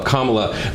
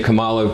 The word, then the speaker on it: Kamala: Kama, like the punctuation mark, Kamala. But people on the right love to say Kamala because they think mispronouncing her name is somehow, you know, puts her down.